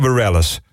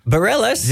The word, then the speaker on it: Bareilles: Sarah Burrell? – Bijna goed. Dit is waar ik op gehoopt had. Sarah Bareilles. – Bareilles? – Ja. – Oh. – Ja, schijnlijk Sarah Bareilles zeggen.